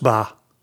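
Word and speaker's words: beatha: That's pronounced ba. B-E-A-T-H-E-A.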